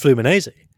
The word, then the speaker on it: Fluminense: So I've never pronounced it. Fluminense? – Well, I think it's just Fluminense. – Fluminense. Okay. – I think for whatever reason, the N, I think it's just Fluminense. I might be wrong on that.